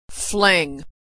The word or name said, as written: fling